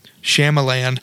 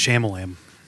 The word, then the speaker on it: Shyamalan: Shyamalan. – Did you correct me or was you just joking? Is that really how you say it? – His name is Shyamalan.